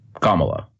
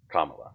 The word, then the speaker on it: Kamala: Am I pronouncing that right, by the way? Kamala. Kamala. Okay. – Yeah.